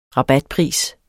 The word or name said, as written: rabatpris